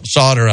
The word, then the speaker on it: Sauter: So Sauter, I think you pronounce that.